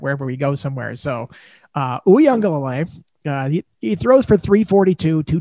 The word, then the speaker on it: Uiagalelei: We should talk about Uiagalelei, and I'm going to keep saying his name because I learned how to pronounce it.